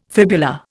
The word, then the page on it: fibula: Indian